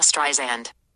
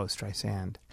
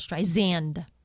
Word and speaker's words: Streisand: Barbra Streisand. – Streisand. Oh, Streisand. – Yeah, it's more the Z sound. Streisand. Rather than sand.